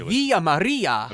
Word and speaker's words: Villa Maria: Yeah, actually, Tim, it is Villa Maria. – That's what I was going to say. – You have to roll your R, too. – Villa Maria in Bryan.